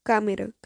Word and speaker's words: Câmara: Abalos que atingiram João Ca, Camero, Câmara, Câmara, João Câmara.